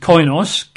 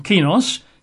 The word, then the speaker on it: koinos: Now, the word defile in the Greek is usually pronounced koinos, K-O-I-N-O-S. I think some speakers reckon kinos, K-O-I-N-O-S, which means spiritually desecrated.